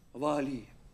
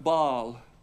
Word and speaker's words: Баал: И не будешь более звать меня Ваали. Ваали или Ваал. Или правильно оно произносится как Баал.